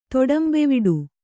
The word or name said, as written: ತೊಡಂಬೆವಿಡು